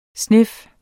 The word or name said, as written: snif